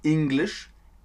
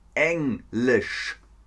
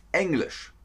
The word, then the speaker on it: Englisch: Nicht Englisch, Englisch. S-C-H. Englisch.